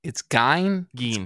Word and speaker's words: Gein: Gein. – It's Gein. Gein. They even make a big point in the book about the pronunciation.